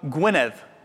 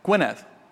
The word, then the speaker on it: Gwynedd: So Eisteddfa, and then it's in the town of Gwynedd. Gwynedd, that's the county where we were going.